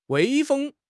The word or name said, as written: 威风